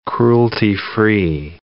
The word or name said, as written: cruelty-free